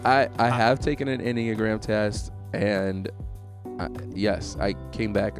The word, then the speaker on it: Enneagram: We were talking about the Enneagram. Is that how you say it, right? – Yeah, it is.